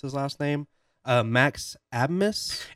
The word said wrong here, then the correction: Asmus: It's pronounced Asmus. It doesn't make any sense.